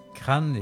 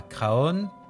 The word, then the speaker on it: Craon: Alors, si vous vous rendez aujourd'hui à Craon, plutôt, comme on le prononce là-bas, Craon et pas Craon, je viens de vous dire pourquoi on prononce comme ça.